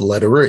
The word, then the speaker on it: Lederer: And my name is Bob Lederer. It's spelled L-E-D, like David, E-R-E-R. It's like Roger Federer, the tennis player, only with an L instead of an F.